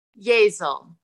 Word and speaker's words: Yazel: How do we pronounce the last name? – Yazel. – Okay, Yazel. All right. I didn't know if it was Yazel or... I was going with Yazel, just rhyming with Giselle.